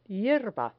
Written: hierba

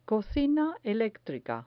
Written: cocina electrica

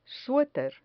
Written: sueter

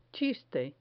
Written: chiste

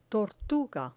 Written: tortuga

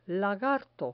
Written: lagarto

